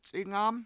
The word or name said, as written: c$ingAm